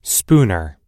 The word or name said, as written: spooner